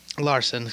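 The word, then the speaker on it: Larsson: Anyway, Oliver Ekman-Larkin. Larson, excuse me. Did it again.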